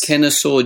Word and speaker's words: Kennesaw: Okay, now you're in, I hope I pronounce this correctly, Kennesaw? – Yes. – Kennesaw, Georgia in the USA.